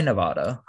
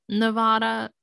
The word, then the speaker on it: Nevada: Nevada? – Oh, you say Nevada? – Is it Nevada? I think it's in Nevada. – It's somewhere by around. – What do you say? – I say Nevada.